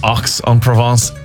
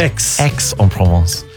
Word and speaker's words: Aix-en-Provence: Say, please stop saying Aix-en-Provence. It's hard of the years. It's pronounced Aix-en-Provence.